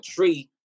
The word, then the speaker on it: Twi: but but Tree, from what I know, is, is one of the uh, the major languages. Yeah, TWI pronounced tree.